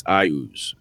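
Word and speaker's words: Iuz: And then he's like, well, actually, in the book, it specifically states it's Ayuz is how it's pronounced.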